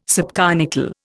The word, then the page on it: subconical: Indian